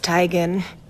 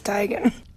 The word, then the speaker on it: Teigen: And Chrissy Teigen just blew everybody's mind yesterday by saying we've been pronouncing her name wrong all along. – I'm tired of living this lie. It's Teigen. Isn't it, Mom? – Yep. Yep. – I told you, it's Teigen.